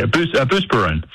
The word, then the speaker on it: Buspirone: I mean, she was on a boatload of Buspirone. Am I saying that correctly? Probably not. – Yeah, Buspirone.